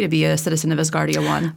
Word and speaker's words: Asgardia: Asgardia 1, did I pronounce that right, Emily? – Asgardia 1, that's right.